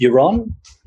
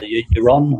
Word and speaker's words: Jerome: It's pronounced Euron. – Euron. – Yeah, the J-E-R is like a Y sound. – Right. – So Euron, or if you're in Holland, Euron.